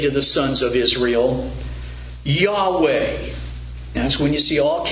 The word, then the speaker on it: Yahweh: So whenever you see in most Bibles all capital letters for the word Lord, that's the word best pronounced Yahweh.